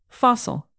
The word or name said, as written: fossil